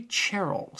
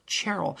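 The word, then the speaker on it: Cherrell: But in Flowering Wilderness, he has a really wonderful clergyman named Hilary Cherrill, spelled Charwell, but pronounced Cherrill, an upper-class titled man who's gone into the church.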